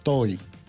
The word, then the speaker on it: Tohi: T-O-H-I. TOY. – A-T-O-H-I. – Sí. – Como Tojí. – Sí. – Tojí y se pronuncia TOY.